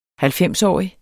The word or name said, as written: halvfemsårig